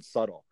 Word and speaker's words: subtle: S-U, and then they're like, there's a B in there. It's a silent B in the word subtle.